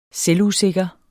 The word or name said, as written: selvusikker